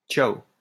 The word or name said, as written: ciao